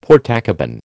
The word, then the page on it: Portakabin: American English